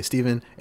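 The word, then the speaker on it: Stephan: My name's Steven, spelled with a PH and an A. It's like Stefan, but we say Steven.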